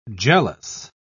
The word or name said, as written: jealous